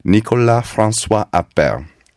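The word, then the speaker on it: Nicolas François Appert: And it was someone, and that someone was a chef and confectioner named Nicolas Francois Appert. Abhi, you took French. How's, uh, how's my pronunciation? – As far as I know, perfect.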